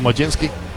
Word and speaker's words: Mlodzinski: Majinski, que para nada se deletrea en cómo se pronuncia. Y miren cómo se deletrea: M-L-O-D-Z-I-N-S-K-I. Pronunciado Majinski.